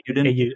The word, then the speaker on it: Ayuden: I assume it's pronounced Ayuden. Ayuden? – Ayuden, yeah. Ayuden.